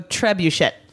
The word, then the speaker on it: trebuchet: All right, mine is the Eskimo trebuchet. – Trebuchet. – No, it's this pronunciation. That's why. – It's Shay.